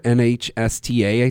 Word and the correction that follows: NHTSA: So NHTSA, sorry. NHTSA is how you pronounce it.